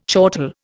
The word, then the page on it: chortle: Indian